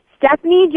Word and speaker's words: Stefani: It's Stefani, but it's spelled S T E F A N I. Stephanie Joanne Angelina Gurmanatata.